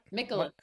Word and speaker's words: Mikolas: Mikolas. Mikolas? Is that right? – Mikolas. Like Nicholas with an M.